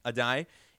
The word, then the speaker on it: Addae: How do you say it? Addae. – Addae is the cornerback's coach from the University of Miami.